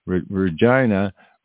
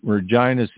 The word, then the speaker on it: Regina: Face the backlash after certain slogans and phrases joking about Regina rhyming with vagina appeared on the agency's social media.